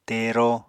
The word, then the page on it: tero: Apatani